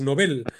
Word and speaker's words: Nobel: Por cierto, creo que el nombre, el modo correcto de pronunciar el premio es Nobel. No tendemos a llamarle Nobel a alguien que debuta, que es novato, que tiene poca experiencia, mientras que al premio le reservamos la pronunciación como Nobel.